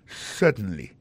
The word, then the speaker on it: certainly: Alors, si besoin appuyer, mettez plus jevril dans yes là, ou dit yes, certainly. Alors, en parlant de accent, gardez bien pour un mot certainly, accent tombé sur le premier syllabe là.